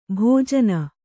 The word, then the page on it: ಭೋಜನ: kannada; bhōjana